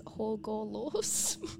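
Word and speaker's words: Hagalaz: It's a little, actually, there's a little pronunciation thing here. It says Hogalos.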